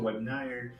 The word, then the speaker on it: webinar: Então você vai encontrar muito webinar. Falei certo? Falou.